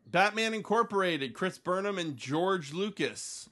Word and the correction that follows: Jorge: I don't think maybe that's not pronounced George. – I don't believe that's no, that's Jorge. – Oh, Jorge.